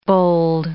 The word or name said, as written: bold